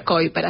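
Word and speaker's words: Kuyper: Así que vamos a cerrar este recorrido por la vida y también algo de la obra de Elizabeth Kuiper, que aclaramos que se escribe Kuiper el apellido, con I, con K y con Y, Kuiper, pero se pronuncia Kuiper.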